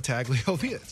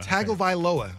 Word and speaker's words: Tagovailoa: Tagovailoa. – I believe. – Tua Tagovailoa. I'm going to learn how to say this. – You pronounce every vowel in the Hawaiian or Polynesian last name.